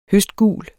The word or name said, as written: høstgul